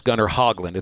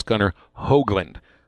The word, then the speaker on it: Hoagland: But it isn't pronounced Gunnar Hogland. It's pronounced Gunnar Hoagland.